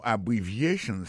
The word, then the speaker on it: abbreviations: En parlant de accent, accent tonique, qui j'en pour nous prononcer mot, n'a pas un mot abbreviations, qui signifie abréviation. Abbreviations, accent tonique là, tombé sous troisième syllabe là.